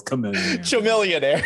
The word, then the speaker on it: Chamillionaire: Millionaire, Bun B, these are guys. – Millionaire. – Did I say it wrong? – He said Chamillionaire. It's Chamillionaire.